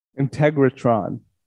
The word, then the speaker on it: Integratron: again, uh, it's called um, integration. Integratron. Oh, Integratron, spelled the same way as integration would be. Um, oh no, no, there's an R.